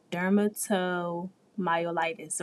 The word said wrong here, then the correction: dermatomyositis: right? That's it's bad. I'm not a nurse and I don't know how to say it. That is horrible. – It's okay. It took me a while too. Okay, girl. It's called dermatomyositis. – Okay, yeah. That. I was way off.